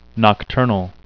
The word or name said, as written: nocturnal